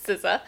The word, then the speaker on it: SZA: SZA.